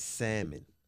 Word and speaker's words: salmon: It's salmon. – I say salmon. – I say salmon. – People say salmon. – It's salmon. – Look, the L is silent.